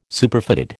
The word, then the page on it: Superfatted: American English